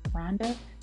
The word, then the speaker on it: Rhonda: You can find me as Only One Rhonda, and that's spelled out as Only One. There's no numbers. And my name is spelled R-H-O-N-D-A.